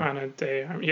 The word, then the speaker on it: Ana de Armas: Ana de Armas.